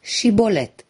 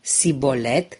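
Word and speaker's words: Shibboleth: Evreii din Galaad îi puneau pe cei din tribul lui Efraim să pronunțe Shibolet. Dacă aceștia spuneau Sibolet cu S, erau identificați drept dușmani și uciși.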